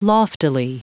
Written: loftily

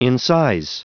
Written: incise

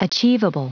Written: achievable

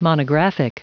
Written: monographic

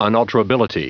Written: unalterability

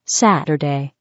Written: saturday

